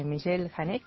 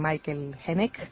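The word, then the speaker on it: Michael Haneke: ¿Te acuerdas de aquella película de Michel Haneck? Le dicen algunos autores, le dicen Michael Haneck, pero en realidad es una película que tiene origen francés y por eso la pronunciamos así atrevidamente.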